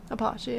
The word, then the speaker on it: Apache: Would you say Apache? – Apache, yeah.